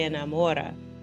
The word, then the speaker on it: enamora: And note how the stress changes on the syllable here when we conjugate. Se enamora, okay, versus enamorarse.